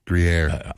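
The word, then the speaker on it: Gruyère: Gruyere? Amazing. – No, how do you say it? – Gruyere. – I was close. I say Gruyere.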